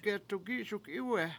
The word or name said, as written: get$ukISukIwe7